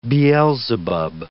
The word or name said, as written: Beelzebub